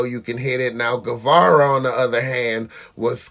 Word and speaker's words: Guevara: The opening match was one of my favorites, Sammy Garvey. you know. – Guevara.